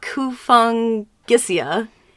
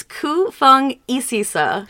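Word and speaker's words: Kufungisisa: It's not Kufung Gisia. It's Ku Feng Isisa.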